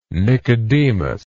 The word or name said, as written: Nicodemus